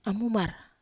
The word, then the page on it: amumar: Wamesa